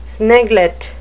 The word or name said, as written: sniglet